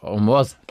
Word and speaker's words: Hormus: Und das Wort wird doch tendenziell, sagt er, eher auf der zweiten Silbe betont. – Also Hormus. – Hormus. Hieß auch mit einer Tonerhöhung. – Ja. Mhm. So wie man auf Apfelmus.